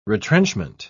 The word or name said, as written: retrenchment